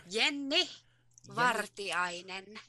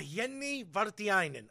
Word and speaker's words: Jenny Vartiainen: Jenny Vartiainen? – Jenny Vartiainen. – Ah, Jenny Vartiainen.